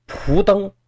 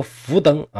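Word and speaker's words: 苻登: 还是五胡乱华，这五个族当中有一个氐族，氐族有这么一位蒲登，或者叫福登啊，这个多音字。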